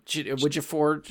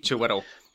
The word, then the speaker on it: Chiwetel: Chidi, I always butcher his name. Chiwetel. – Yep. – Chidi. – Chiwetel. – Yeah. Chiwetel Ejiofor.